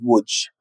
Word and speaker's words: Łódź: Till exempel då de som kommer från eh, Lodz, eller som det polska uttalet Łódź.